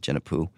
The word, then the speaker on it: Djenepo: It's spelled Djenepo, but it's pronounced Djenepou.